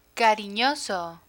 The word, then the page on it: cariñoso: Spanish